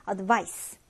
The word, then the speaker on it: advice: 그런데 발음 같은 경우에는 이제 동사는 Z 발음해줍니다. Advise, advise 이렇게 조금 더 무거운 발음이 나고요. 한편 명사는 S 발음을 합니다. 그래서 조언이라고 할 땐 조금 더 가볍게 advice 이렇게 발음하면 됩니다.